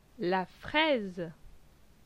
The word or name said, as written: La fraise